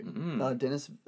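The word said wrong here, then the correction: Denis: uh, Dene, sorry, his name's Dene.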